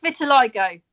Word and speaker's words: vitiligo: It's vitiligo.